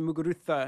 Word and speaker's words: Muguruza: It's spelled with a Z. I always like that. They say Muguruza because it's kind of got that lisp, that the Barcelona lisp kind of thing they do.